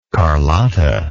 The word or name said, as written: carlotta